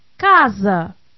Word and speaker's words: Casa: Between vowels, an S is always pronounced as English Z. – Blusa. – Casa.